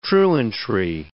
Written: truantry

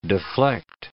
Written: deflect

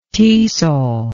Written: tesol

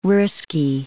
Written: Russki

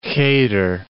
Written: cheder

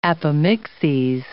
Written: apomixes

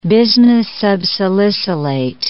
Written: bismuth subsalicylate

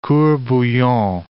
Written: court bouillon

